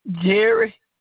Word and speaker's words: Jerry: Jerry. – Can you spell that? – J-E-R-R-Y. – C-O-X.